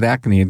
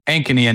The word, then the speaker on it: Ankeny: I always have a problem with Ankeny. Did I say it right? – Ankeny and Des Moines. – Ankeny. I got to put the line over the A, Ankeny.